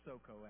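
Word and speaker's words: Socoh: Now the Philistines gathered their armies together to battle, and were gathered at Sukkot. Or Soko, actually, is how you say it.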